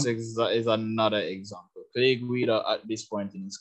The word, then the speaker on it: Guida: Guida? – Clay Guida.